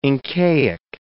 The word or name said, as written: Incaic